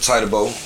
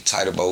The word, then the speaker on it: Titaboe: You spell that T-I-T-A-B-O-E, Tidy Bo, once again, just for the pronunciation for the people when they see it.